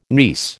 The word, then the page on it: niece: American English